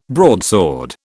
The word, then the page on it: broadsword: British English